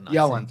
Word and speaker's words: Eoin: How do you pronounce E-O-I-N? I meant to look this up. – Yoan, thank you.